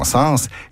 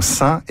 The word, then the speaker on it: sens: En revanche, dans l'expression ça n'a pas de bon sens, il est recommandé de prononcer l'S final de sens. Il suffit de penser à l'expression synonyme ça n'a aucun sens, dans laquelle l'S final de sens est bien sonore.